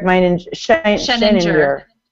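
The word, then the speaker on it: Sheninger: He says that NMHS principal, who is Eric Meininger, I always say his name wrong too, but... Eric Scheninger, thank you, is an expert when it comes to school social media and policies, and that's totally true.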